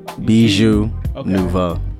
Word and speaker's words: Bijou Nouveau: Bijou Nouveau. – Like, okay, kind of be like, it's pronounced, it's said like B-I-J-U. – Yeah, it's like, it's like bejeweled, but bijou. – No, I don't think it's a good way, but I think it's B-I-J-U.